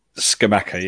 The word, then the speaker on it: Scamacca: Because I know you've got the Italian striker up front, which name who I'm probably not going to try and pronounce, Scamacca? – Scamacca, yeah.